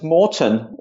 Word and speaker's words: Morten: And then clearly, there was drop me an email, which is Morten, my first name, MORTEN, at Wohn Homes.